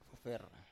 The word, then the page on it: fofer: Biak